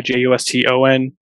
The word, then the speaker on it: Juston: JustOn.